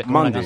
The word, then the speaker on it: Mendy: Este es Mandy. Mandy. – Pero si es con E, Mendy. – Pero en francés se pronuncia Mendy.